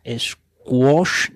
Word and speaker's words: Squoosh: Squish es la aplicación que traigo aquí bueno, es un nombre un poco raro, yo lo reconozco, eh, pues se, vamos, se pronuncia squash, ¿no? Porque es S-Q-U-O-O-S-H,